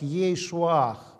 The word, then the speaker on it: Иешуа: И Иисус на еврейском было и Иешуа произносится как Ейшуах.